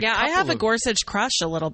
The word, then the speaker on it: Gorsuch: Is that how he pronounces his name? It's Gorsuch, right? – Gorsuch, yes. Yeah.